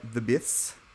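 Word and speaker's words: The Beths: Not Running by The Biths. They're from New Zealand, so you have to say it like that.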